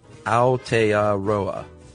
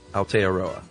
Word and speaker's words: Aotearoa: Aotearoa, or land of the long white cloud, is pronounced Aotearoa. Aotearoa.